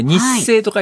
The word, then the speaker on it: 日生: 日生と書いて日生って読む、はい、あの日本生命の日生と書いて、岡山県ではこれ日生と読むんですが、有名なマリーナとかですね、リゾートホテルみたいなものがあって、すごくいいとこなんです、うん、日本のエーゲ海と言われているところでございます。